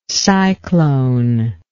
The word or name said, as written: cyclone